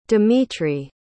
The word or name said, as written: dmitry